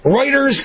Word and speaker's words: Reuters: Reuters kind of rhymes with liars.